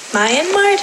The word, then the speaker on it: Myanmar: which is now called Myanmar.